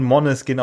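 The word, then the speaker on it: Måneskin: Es, wird, es wird, es wird äh, auf Dänisch schon Moneskin ausgesprochen, weil das hat das A mit diesem Kringel oben drauf ist.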